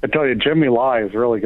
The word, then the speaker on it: Lai: The news out of Hong Kong today was a 71-year-old Jimmy Lai. Is that how you say it? L-A-I, Jimmy Lai, a business tycoon arrested.